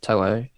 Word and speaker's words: To'o: There, Garrick's probably a bit of a risk, as we just said, but To'o, however you say it, is the correct pronunciation, actually.